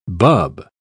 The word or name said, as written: bub